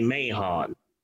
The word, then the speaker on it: Mahon: So a leading director in the world of Nudie Cuties was a guy by the name of Barry Mahon. – Yes. – I think the H in his last name is silent, but I believe that all letters matter.